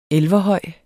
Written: elverhøj

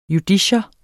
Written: JUDICIETS

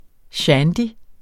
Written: shandy

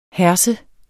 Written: herse

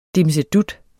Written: dimsedut

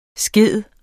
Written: skide

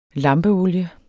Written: lampeolie

